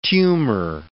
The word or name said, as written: tumour